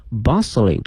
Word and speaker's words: bustling: 哎，这里面看一下这个海口啊，这个马路的前面跟了一个形容词叫 bustling, B-U-S-T-L-I-N-G, bustling, 同时注意一下这个 T 是不发音的，